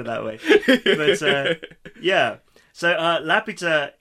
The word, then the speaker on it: Laputa: If you pronounce it that way, it's fine. – Yeah. – Just don't put the accent on the U. – Yeah, so Laputa is this...